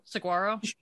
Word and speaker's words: Saguaro: Saguaro, S-A-G-U-A-R-O. – Saguaro? – Saguaro? – Yeah, Saguaro.